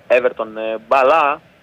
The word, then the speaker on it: Έβερτον Μπαλά: Σεραφίμοφ θα δώσει στον Έβερτον μπαλά, όπως προφέρεται το όνομα του Βραζιάνου επιθετικού.